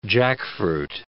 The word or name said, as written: jackfruit